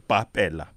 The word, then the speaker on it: Bapela: Mr. Bapela, am I pronouncing your name correct? Bapela? – Bapela. B-A-P-E-L-A. – Bapela, right.